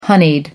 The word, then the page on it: honeyed: American English